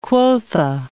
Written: quotha

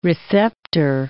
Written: receptor

